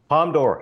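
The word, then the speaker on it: Palme d'Or: Is it Palm Dior? I don't know the pronunciation. – Palm d'Or. Samson got it right.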